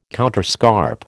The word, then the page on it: counterscarp: American English